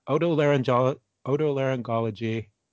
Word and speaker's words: Otolaryngology: He's also an associate professor at the Oregon Health and Science University Department of Otolaryngology. Am I saying that right? – That's pretty good, man. That's a tough word.